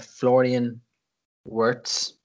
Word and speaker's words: Florian Wirtz: We were asked about Florian Wirtz. Did I pronounce that right, Chris? – Yeah, you did. Yeah, from Bayer Leverkusen.